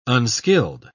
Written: unskilled

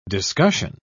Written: discussion

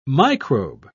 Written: microbe

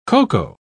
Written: cocoa

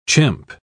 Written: chimp

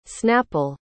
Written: snapple

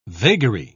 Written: vagary

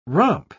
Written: romp

